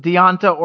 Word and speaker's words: D'Onta: And maybe that's why we saw the introduction of Devontae Foreman. Is that his name? Foreman, yeah. – Last week. – Yeah, I think they pronounce it Deonta or Donta, yeah. Like D'ONTA.